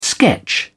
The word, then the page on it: sketch: British English